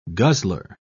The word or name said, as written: guzzler